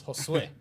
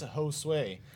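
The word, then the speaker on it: Josué: My name has an accent on the end, so it's Josue. People who have an American accent, it's Josue.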